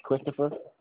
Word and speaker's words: Christopher: Yes, Christopher. – So is it a short C-H-R-I-S-T-O-P-H-E-R, right?